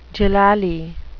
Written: doolally

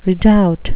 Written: redoubt

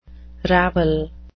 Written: ravel

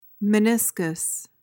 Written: meniscus

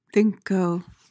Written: thinko

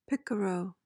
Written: picaro

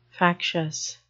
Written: factious

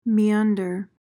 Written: meander

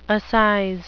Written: assize

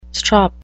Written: strop

